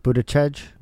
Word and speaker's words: Buttigieg: You pronounce it Buttigieg.